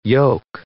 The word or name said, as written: yogh